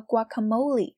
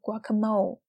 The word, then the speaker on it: guacamole: Wow, this guacamole has got a kick. I'm gonna run to the little girl's room. You guys go ahead. I might be a while. – 我们来看啊，这 Gabrielle 说， this guacamole has got a kick. 那这个 guacamole 呢，就是牛油果酱。大家注意，这个 guacamole 它是西班牙语，所以呢，我们在念的时候不要念作了 guacamole。